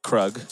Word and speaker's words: Krug: One of the artists performing tonight goes by the name of Krug. – Yes, sir. – It's a proper noun. It's like drug, pronounced with a K.